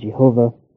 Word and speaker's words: Jehovah: Now, how that name, YHVH, occasionally is pronounced as Yahweh, sometimes as Jehovah.